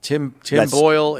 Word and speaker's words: Tim Boyle: Tim Boyle.